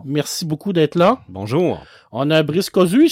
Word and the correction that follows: Cossu: Si je prononce mal les noms, dites-moi-les, hein, parce que... – Cossu. – Cossu, parfait.